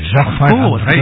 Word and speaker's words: Jacquemart-André: Arnaud, you say it like a Frenchman. Jacquemart-André.